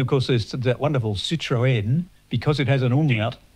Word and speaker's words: Citroën: But yes, pronounced Citroën.